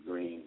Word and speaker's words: Green: Hit me up on Facebook, Daniel Green. My name is spelled DANYEIL, Green like the color.